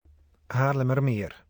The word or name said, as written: Haarlemmermeer